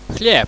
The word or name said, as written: chleb